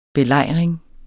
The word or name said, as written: belejring